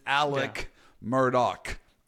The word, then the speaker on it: Murdaugh: In South Carolina when this trial was going on, and they told me, do not be like the rest of the United States who don't know how to pronounce this name correctly. It's a hard K sound. It's Alec Murdaugh.